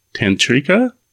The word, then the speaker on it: tantrika: So let's get into a little bit of the tantrika. Did I say that right? Okay. – Yes, yes.